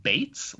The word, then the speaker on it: Beetz: Yeah, so first of all, I just learned recently that this actress's name is pronounced Zazie Beetz, like as in Bates Motel.